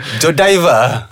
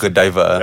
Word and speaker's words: Godiva: Godiva. – Oh, that's how you pronounce it. – It's pronounced Godiva.